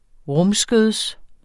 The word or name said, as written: rumskøds